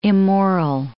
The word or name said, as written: immoral